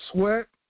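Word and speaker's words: Swett: Sweat, South Dakota.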